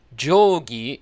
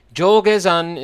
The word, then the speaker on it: Jogezan: Look at the blog post to see the characters, and the first two characters read Jogi in standard Japanese, but that's how Tokyoites say it, and this is Sendai. Place names don't quite work the same way, at least historically. Jogezan is the local pronunciation.